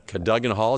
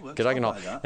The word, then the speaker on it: Cadogan Hall: Ronnie Scott's were doing a couple in late November, and at Cadogan Hall. Is that how you say it? – Yeah. – I've heard different things, but Cadogan Hall. – Yeah, that works. – Okay, Cadogan Hall.